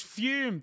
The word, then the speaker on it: Fum: It's Fume. That's spelled F-U-M, but pronounced like there's an E on the end, but the E isn't there.